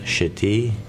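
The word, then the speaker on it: Ch'ti: And this is going to sound odd, but this is how it's pronounced. Chati.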